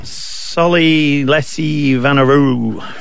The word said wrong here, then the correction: Vunivalu: How do you say it, Smithy? – Solly Lassie Vunivalu.